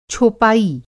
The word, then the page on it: छोपाई: Hindi